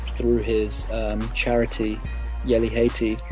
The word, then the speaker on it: Haiti: Haiti, Haiti, even. That's how you pronounce it, isn't it? Is it Haiti? Yeah. Yeah. Good.